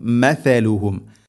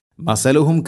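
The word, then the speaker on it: മഥലുഹും (مثلهم): മഥലുഹും, മസലുഹും കമസലി എന്ന് ഓതരുത്.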